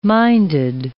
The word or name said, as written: minded